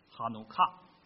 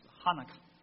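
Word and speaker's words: 哈 努 卡 (חנוכה): 那这个英文里面的 dedication 啊，这个奉献哈、啊，它对应的那个希伯来文呢，它是这么念的，叫哈努卡，它英文的发音是哈纳卡。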